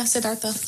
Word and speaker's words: Siddhartha: I was thinking about when they talked about Buddhism and they said that when Siddhartha, is that how you say it? – Yeah, Siddhartha.